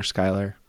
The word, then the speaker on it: Schuyler: That's how we know we're pronouncing his first name properly. – Right. – Smiler, Schuyler, get yeah.